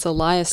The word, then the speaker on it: Eliasson: I actually know how to pronounce that one. It's Eliasson.